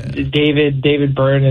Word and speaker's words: Byrne: But I have actually been getting kind of into, like, the Talking Heads lately, because David Byrne is like, did I correct that? Did I say that right? – Yes.